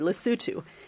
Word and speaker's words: Lesotho: Which, if memory serves me, he said it correctly, Lesotho.